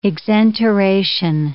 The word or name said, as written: exenteration